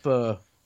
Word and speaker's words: pho: It's pronounced pho.